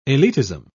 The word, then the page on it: elitism: British English